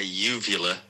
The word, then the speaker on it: uvula: Also, it's a uvula, not a uvulva.